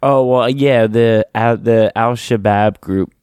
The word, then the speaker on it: Al-Shabaab: people, like over 100 people rescued just dealing with this attack from Al-Shabaab.